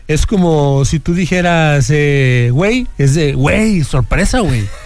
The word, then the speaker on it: güey: en vez de pronunciar pues güey, tal cual, lo empezaron a pronunciar como güey con W. – Oye, güey, ¿qué tranza?